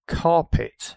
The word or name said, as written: carpet